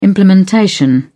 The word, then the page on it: implementation: British English